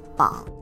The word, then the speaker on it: 膀: can also mean wing, and as such is pronounced as bong, bang.